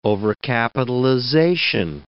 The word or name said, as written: overcapitalization